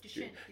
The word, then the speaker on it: Duchenne: Duchenne, yeah.